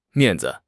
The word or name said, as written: 面子